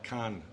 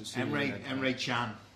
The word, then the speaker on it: Emre Can: Who is this other fella? Khan? Is that how you pronounce it? – Emre Can.